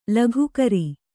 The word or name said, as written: ಲೋಕಾಕಾರ